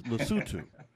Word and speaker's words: Lesotho: Because, and I now understand it's Lesotho. And I said it wrong for years.